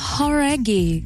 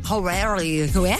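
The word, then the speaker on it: Jauregui: É Lauren Horaghi. Talvez você já tenha ouvido falar dela. Ex-integrante do grupo Fifth Harmony. – Horaghi. – Horaghi. Então a gente tava falando errado, cara.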